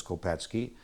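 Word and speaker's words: Kopecky: And he says Kopetsky. Close enough.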